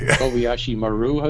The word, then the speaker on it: Kobayashi Maru: Kobayashi Maru, is that how you pronounce it? – Exactly. – That's exactly it.